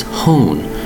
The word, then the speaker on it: hone: Today's word is hone, H-O-N-E. The word is hone, pronounced in one syllable, hone, H-O-N-E.